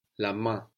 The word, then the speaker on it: main: And all of this is coming from the base, la main, which means the hand. And this is spelled M-A-I-N. We don't hear that N. – La main, the hand.